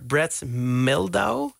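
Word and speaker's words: Brad Mehldau: Dat is een cover van Blackbird van de Beatles, gespeeld door Brett Meldau.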